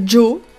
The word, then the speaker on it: JAAW: Bon, et eh ben on enchaîne sur un morceau d'un groupe qui s'appelle Joe, mais ça s'écrit J-A-A-W.